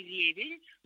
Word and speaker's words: ревень: Да, да, многие говорят «щавель» и «ревень», но на самом деле это «щавель» и «ревень». И вот в этих словах, когда мы начинаем склонять, у них ударение переносится на окончание не «щавеля», а «щавеля», не «ревеня», а «ревеня». Да?